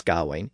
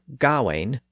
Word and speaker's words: Gawain: Generally speaking, the AIN spelling represents the AIN sound in modern English, as in words like pain and chain and stain. So the most common modern pronunciation is either Gawain or Gawain, depending on which syllable you stress.